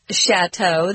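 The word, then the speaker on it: château: It's a French word, chateau. That's why we pronounce it kind of funny.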